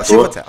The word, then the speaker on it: Chiwetel: O Mordo, eu vou chamar de Mordo, eu não sei pronunciar o nome do Chiwetel. – Chivo Hotel, tá bom.